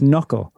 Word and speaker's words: knuckle: that silent K at the beginning of it. So it, it looks. Um, it looks like it's got a rhyme in it, but K N U C K L E knuckle.